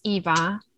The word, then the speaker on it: Iva: So my name is Eva, which a lot of people get confused because it's spelled IVA.